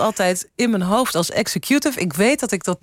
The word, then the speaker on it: executive: Want de vraag is, hoe spreek je het woord executive uit? – Nou, executive. – Als executive, zou je zeggen. Maar dat deed ik niet. Ik zei executive. ik weet eigenlijk ook niet waarom.